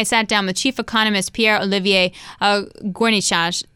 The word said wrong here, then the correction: Gourinchas: Nope, I said that wrong. Gourinchas. There we go.